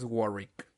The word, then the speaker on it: Warwick: Pues según encontré eh, que la pronunciación, la pronunciación es Warwick, pero puede estar mal. Se escribe Warwick.